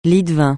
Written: lie-de-vin

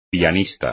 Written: pianista

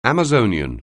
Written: Amazonian

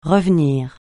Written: revenir

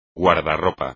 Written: guardarropa

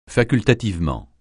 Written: facultativement

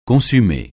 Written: consumer

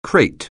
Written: crate

